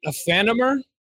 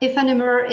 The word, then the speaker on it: Aephanemer: In fact, you have to pronounce it like in French, because right. It's like the merge of two French words. So it should be Aephanemer, but Aephanemer is really great.